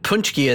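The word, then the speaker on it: paczki: But it's Punchki is, do you know how it's spelled? – Yeah, it's spelled like Paxki. – Yeah, P-A-C-Z-K-I. I'm like, what the hell?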